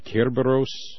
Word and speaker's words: Cerberus: I guess the best ancient Greek pronunciation would be Kerberos, or something close.